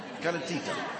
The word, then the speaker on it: calentita: The Best of Gibraltar also tells us, I quote, "The national dish is calentina." Have I pronounced it right? Calentita. Calentita. The national dish is calentita from Genoa, best described as a thick pancake served with bread and butter.